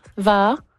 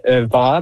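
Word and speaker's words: va'a: Donc on prononce va. – Oui, en Polynésie, justement, on prononce va, mais il y a différents noms partout dans le monde.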